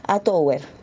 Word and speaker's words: A Tower: se pronuncia directamente como se escribe. A Tower, a los chilensis.